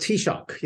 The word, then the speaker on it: Taoiseach: You talk a lot about, and I'm not sure how to pronounce the name of the Taoiseach, is that how you pronounce? – It's called Taoiseach, yeah, which is the Irish for prime minister, basically.